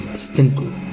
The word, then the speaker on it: Stanko: siendo este Steve Stacon el ganador del primer Mister Universo. – Steve Stacon, ¿no? – Stacon, Stacon. Stanco. Bueno, depende como la pronunciación y el acento. Steve Stanko.